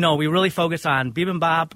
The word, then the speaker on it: bibimbap: Bibimbap. – And that's the traditional Korean rice bowl. – Is that the right way to say it? Bibimbap? – Bibimbap. Yep.